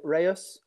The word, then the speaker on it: Reus: Is it CF Reus, CF Reus? – I think that the locals used to tend to call it Reus. – Reus. – But Brits do tend to call it Reus or Reus.